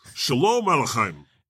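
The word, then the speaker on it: Sholem Aleichem: First, but from Gianmarco, help me how to say this. Shalom Aleichem. Am I saying that right? – Oh, man. – Shalom? – Aleichem is the last name. – Playwright of Fiddler on the Roof. Shalom Aleichem. – That sounds good.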